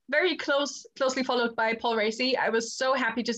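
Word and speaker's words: Raci: Paul Ricey. I guess that's how you say it, from Sound of Metal, and Lakeith Stanfield for Judas and the Black Messiah. – Yes, it's Paul Ricey.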